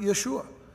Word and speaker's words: Yeshua: This is his name actually is pronounced Yeshua, and so he's a type of Christ in a sense, as with Zerubbabel.